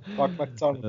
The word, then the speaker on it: Doug McDermott: Ee, ben işte o Doug Dormund diye mi okunuyor o çocuk? Doug McDermott.